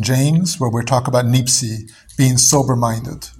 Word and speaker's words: nepsis: Was it Nepsis? – Nipsi, yes, which is the Greek way of pronouncing, but it's N-E-P-S-I-S.